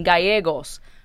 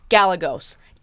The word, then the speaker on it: Gallegos: Pero second of all, people cannot pronounce that shit to save their goddamn lives. – Galagos. I have seen Galagos. Galapagos. Like,